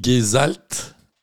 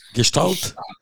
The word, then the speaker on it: Gestalt: Ah, attends, es en train de dire que dans le Gesalt, je le prononce bien. Gestalt.